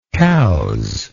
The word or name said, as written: Cowes